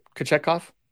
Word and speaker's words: Kochetkov: Kochetkov, had a Kochetkov? – Yep.